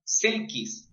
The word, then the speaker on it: selkies: Le llaman selkis. Se escribe selkies.